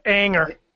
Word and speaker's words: Ayinger: So I'm curious, since I'm going to the, wow, how do I say this? Anger? A-Y-I-N-G-E-R? – Anger. – Anger? – Yeah. – Anger.